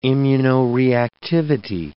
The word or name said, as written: immunoreactivity